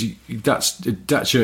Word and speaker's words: Dacia: Because Dacia is how a Romanian person would say it, but you have to allow for sort of sometimes localized pronunciations.